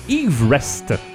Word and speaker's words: Everest: Donc le nom devrait en fait être prononcé Yves Rest, l'accent venant avant le R et non pas après.